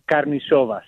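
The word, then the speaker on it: Karnišovas: Stick to Arturas, but the last name it's pronounced Carnishovas. You announce it at the O. Carnishovas.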